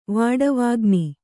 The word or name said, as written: ವಾಡವಾಗ್ನಿ